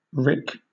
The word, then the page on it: rick: British English